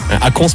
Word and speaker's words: Akon: Akon, ouais, pardon, je prononce ça à la française. – Akon, c'est pas pareil.